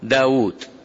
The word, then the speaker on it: داود: فقال قائلهم، قال لأنه سرق واو داود، فداود هناك واو تنطق ولا تكتب، وفي عمرو تكتب ولا تنطق.